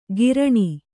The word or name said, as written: ಕಿರಾಣಿ